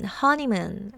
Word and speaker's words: honeymoon: Honeymoon 이 단어 하나에 삼음절로 되어 있는데 강세가 첫 번째의 음절에 옵니다.